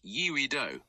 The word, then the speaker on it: Yeouido: Regarding your question, it's pronounced Yeouido, with the first part sounding like Yee-O.